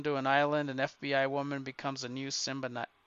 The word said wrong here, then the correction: symbiote: God, I can't say it right now that they've been symbiote. I get confused now, Barb, because we saw the preview and they mispronounce it. Now I'm mispronouncing it. Symbiote.